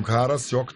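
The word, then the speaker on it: Karasjok: А на карте написано Карасьёк. Эдуард, город Пенза. Это действительно произношение такое? – Карасьёк, да. – Карасьёк. Да. Потому что я слышала только Карашок от слова хара.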